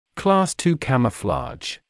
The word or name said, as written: Class II camouflage